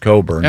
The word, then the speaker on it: Cockburn: It is spelled Cockburn, but it's pronounced Cockburn.